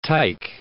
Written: tyke

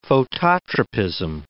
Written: phototropism

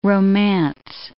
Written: romance

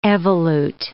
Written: evolute

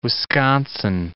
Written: Wisconsin

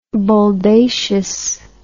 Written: boldacious

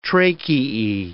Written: tracheae

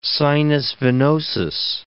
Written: sinus venosus

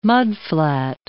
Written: mudflat

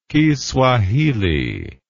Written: kiswahili

